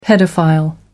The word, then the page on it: paedophile: American English